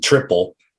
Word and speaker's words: tripel: So as you can imagine, triple, which it's actually spelled T-R-I-P-E-L, but we pronounce it triple as a triple, like a triple in baseball, is the strongest one.